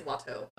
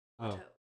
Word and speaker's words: Watteau: But I think it is Watteau. Right? It's spelled like Watt, like a watt of energy, and then O. E-A-U. – Watteau. Oh. E-A-U. – Like Eau de Parfum.